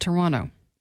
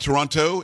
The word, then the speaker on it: Toronto: Now, I don't know if you know this, but you don't pronounce the second T. – I did not know that. Toronto. – It is not Toronto. It is Toronto.